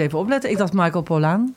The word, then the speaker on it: Pollan: Spreek je het zo uit, Pollan? – Michael Pollan. – Oh, ik dacht Michael Pollan.